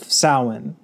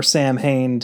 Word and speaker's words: Samhain: And he kind of introduces that concept in Halloween 2 with the writing on the board of Samhain, or Sam Hain to be the regular American pronunciation, but it is Samhain, which is the Celtic holiday of Halloween, essentially.